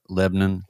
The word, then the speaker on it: Lebanon: Uh, it's spelled Lebanon, but they call it Le- Lebanon.